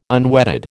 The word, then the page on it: unwetted: American English